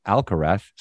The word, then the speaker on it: Alcaraz: And Alcaraz, well, if you were from Latin America, it would be a hard Z. But if you're Spanish, the Z is TH, and he pronounces it Alcaraz. So that's how I said it. I don't know if I'm saying it incorrectly, but I heard him say it that way.